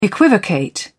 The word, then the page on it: equivocate: British English